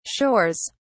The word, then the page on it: shores: British English